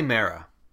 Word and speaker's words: Mera: And how do you, is it Mira or Mera? How do you pronounce her name? – I say Mera.